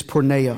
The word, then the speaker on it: porneia: That word fornication in the Greek is porneia. I'm going to spell it for you because I want you to get the seriousness of this. P-O-R-N-E-I-A, porneia.